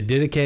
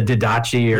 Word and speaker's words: Didache: What did you call that ancient document, the Didache? Is that how you pronounced it? – Yeah, that's how I pronounce it. Other people could say the Didache, or, yeah, you know, yeah.